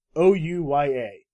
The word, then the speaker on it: Ouya: Ouya.